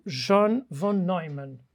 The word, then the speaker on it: von Neumann: Eu vou falar sobre dois vultos da ciência que estiveram ao lado dos aliados, o Alan Turing e o John von Neumann. Penso que será assim que se pronuncia. – Sim, sim.